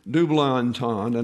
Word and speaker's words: double entendre: The warning contains a double entendre, is the way that's pronounced. Double entendre, an ambiguity of meaning arising from language that lends itself to more than one interpretation.